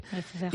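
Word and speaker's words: Lefevere: Y también, por ejemplo, Patrick Lefebvre, que se pronuncia así. Lefebvre.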